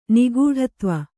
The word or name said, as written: ನಿಗೂಢತ್ವ